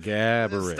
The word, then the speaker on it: Gaborik: It's a Gaborik. Yeah, accent on the first part. Gaborik.